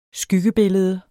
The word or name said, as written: skyggebillede